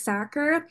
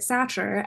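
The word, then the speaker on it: Sachar: From 1998, Holes by Lewis Sacker. We had a book by this author previously, and I pronounced it Satcher, and I just found out that that is wrong.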